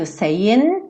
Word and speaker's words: saying: Consoante vogal, porque eu tenho um n, né, do saying, tá terminando no n, já que o g não vai ser pronunciado, e eu já pego no I love you.